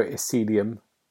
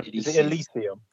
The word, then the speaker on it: Elysium: I've been playing Disco Elysium. Have I said it right? – No. – Elysium. – Elysium, that's it.